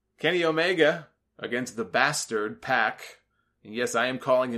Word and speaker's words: Pac: His name is Pac. That's how they pronounce it. That's how I pronounce it. I had a few people, oh, it's Pac, Solomonster. No, it's not. It's Pac.